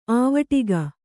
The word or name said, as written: ಆವಟಿಗ